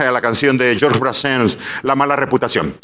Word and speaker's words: Brassens: Brassens.